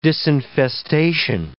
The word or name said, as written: disinfestation